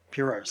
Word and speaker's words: Poirot: Poirot, sorry.